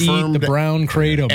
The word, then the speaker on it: kratom: Kratom, whenever you pronounce that. Yeah. – I think it's kratom. – Kratom, yeah.